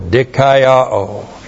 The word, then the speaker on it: dikaiō: D-I-K-A-I-O, long O, dikaiō.